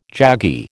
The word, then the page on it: jaggy: American English